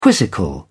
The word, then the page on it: quizzical: British English